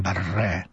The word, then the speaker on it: Barre: Ja pochwaliłem się, że mam lepszą dużo wymowę, bo jak powiedziałem Martin Barre, o gitarzyście, że tak ładnie Barre, i mówię, że to wszystko pana zasługa, bo... – A to chyba źle, bo on chyba Bar się wymawia.